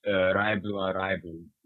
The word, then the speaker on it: Live A Live: I have always pronounced it Live a Live. – We have such diversity in this podcast. – I think Live a Live is the correct pronunciation, though. – It probably is. The whole thing smells like something that maybe makes more sense in, like, English than it does in English. – Although, the Japanese pronunciation is Raibu Araibu, which would translate more into Live Alive. – Oh, yeah. – So, I guess mine is correct, but I prefer Live Alive.